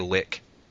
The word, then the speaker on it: lich: Or you could be technical and say lick. It's like L-I-C-H. Because a lick is an actual fantasy creature.